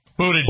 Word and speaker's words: Buttigieg: And as long as Pete Buttigieg, how do you say his name? – Buttigieg. – Close enough.